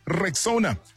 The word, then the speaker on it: Rexona: If you haven't heard, Shield is now Rexona. A brand that you've trusted for years is stepping forward with a bold new move and a bold new name. It's pronounced Rexona.